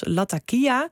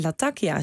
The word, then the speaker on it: Latakia: In het gesprek over Israël sprak de correspondent de plaatsnamen uit als Latakia, terwijl jij Latakia zei.